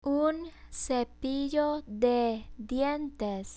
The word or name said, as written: un cepillo de dientes